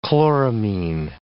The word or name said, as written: chloramine